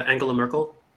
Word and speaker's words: Angela Merkel: So, I mean, you could, or I guess everybody's kind of touched on it as far as Angela Merkel. Did I say that right? – Yeah, Angela Merkel.